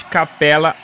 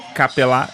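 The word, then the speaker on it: Capela: Na última terça-feira, dia 4 de fevereiro, terminou a história do pivô suíço Clint Capella, ou Capella, depende da sua pronúncia.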